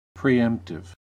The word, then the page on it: preemptive: American English